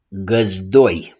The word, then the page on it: GĂZDOI: s.m. și adj.